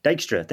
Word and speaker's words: Dijkstra: So while the headliner of the next chapter is, and I'm sorry, I've never pronounced his name, Dijkstra, thank you.